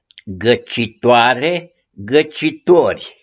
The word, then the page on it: GĂCITUARE: GĂCITUORI